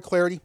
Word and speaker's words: Clardy: You can follow me on Twitter at Troy Clarity. The last name is spelled C-L-A-R-D-Y, at Troy Clarity.